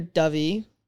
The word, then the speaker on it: Dyfi: It's near Dyfi. That's how you say it.